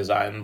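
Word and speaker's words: Zyn: It's Zin, but I think some people say Zion, but it's Zin.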